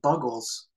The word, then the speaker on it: Buggles: Bugles? – I think it's the Bugles. No, you're right. The Buggles.